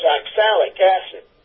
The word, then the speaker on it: oxalic acid: The first item, oxalic acid, it's spelled O-X-A-L-I-C, and A-L, and you would pronounce the gal's name Sally. – Uh-huh. – So it's oxalic acid.